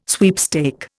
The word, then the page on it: sweepstake: Indian